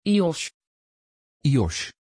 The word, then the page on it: Yosh: Dutch